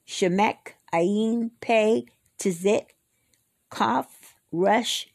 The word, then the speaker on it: resh: The next one is rush, like in run. R-E-S-H. The next one is shin, like in ship. S-H-I-N.